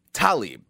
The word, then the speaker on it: Tlaib: Rashida Tlaib, and it is pronounced Tlaib.